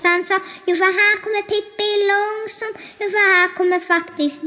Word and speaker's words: Pippi Långstrump: And how do you say that in Swedish? – Pippi Långstrump.